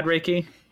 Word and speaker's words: Reiki: So the technique I've been describing is Reiki, R-E-I-K-I, pronounced Reiki.